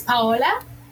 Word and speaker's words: Paola: Again, my name is Paola. And the easiest way I explain pronouncing that is pa, like papa, and then hola. So I am Paola Hernandez Barón, and I am the well-being consultant for our Office of Financial Wellness and Education.